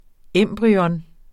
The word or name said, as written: embryo